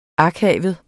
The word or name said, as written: akavet